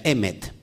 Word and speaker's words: Emet: Lo que tienes en pantalla, tienes tres letras hebreas, Aleph, Men, Taf, y eso significa, eh, bueno, se, pronuncia Emet, y eso es la verdad.